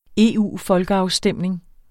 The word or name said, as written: EU-folkeafstemning